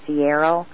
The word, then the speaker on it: Searle: Alan had told me about the company Sierra, and I read it and saw it regarding aspartame and wasn't pronouncing it correctly, so I wasn't connecting the dots. But that company is spelled S-E-A-R-L-E, and as Alan just told us, it is part of the Monsanto company, and Sierra is a pharmaceutical, Alan? – Yeah.